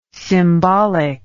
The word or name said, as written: symbolic